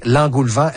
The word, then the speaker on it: engoulevent: Là, Marie-Ève, d'entrée, l'engoulevent, est-ce que je le prononce bien? – Oui, absolument.